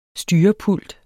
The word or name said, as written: styrepult